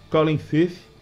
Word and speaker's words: Colin Firth: Colin Firth. Gostou da pronúncia? – Gostei.